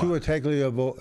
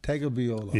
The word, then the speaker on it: Tagovailoa: Tua Tagovailoa. – Tagovailoa.